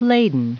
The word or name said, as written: laden